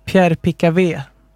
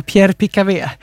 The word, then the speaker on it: Pierre Picavé: Medierapporter tyder på att Pierre Picavtalet, nu ska jag säga uttal, Pierre Picavé, det var inte ett avtal, det var Pierre Picavé, attackerades när han lämnade en bar i stadsdelen Marais i Paris.